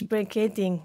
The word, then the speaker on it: brachiating: Yes, brachiating.